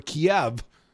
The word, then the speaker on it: Kiev: Attempts to gain time by dragging negotiations will only lead to additional requirements for Kiev in our negotiating position. I pronounce it as the Russians do in this case.